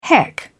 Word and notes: heck: British English

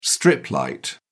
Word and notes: strip light: British English

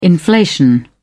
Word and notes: inflation: British English

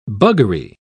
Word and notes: buggery: British English

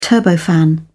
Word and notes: turbofan: British English

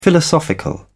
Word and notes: philosophical: British English

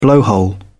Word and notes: blowhole: British English

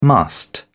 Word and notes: must: British English

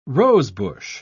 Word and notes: rosebush: British English

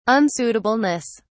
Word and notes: unsuitableness: British English

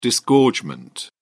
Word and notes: disgorgement: British English

